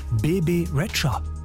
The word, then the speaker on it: Bebe Rexha: aber eigentlich so richtig auf Albanisch wird sie Bebe Rexha ausgesprochen. Als wäre da noch ein D irgendwo, aber gut, wieder was dazugelernt.